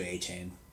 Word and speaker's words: Achane: Well, now it's Devon Achane. We're supposed to say Achane instead of Achane.